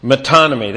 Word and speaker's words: metonymy: This matter of metonymy, faith works in metonymy. This is how you pronounce that word, and this is a figure of speech that is often used in Scripture.